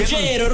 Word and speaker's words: Ruggiero: Y mi querido Ruggiero, lo digo bien. – Ruggiero.